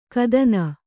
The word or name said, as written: ಘಾತಿನಿ